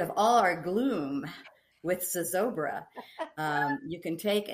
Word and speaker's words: Zozobra: Zozobra.